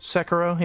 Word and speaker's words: Sekiro: Sekiro, yeah.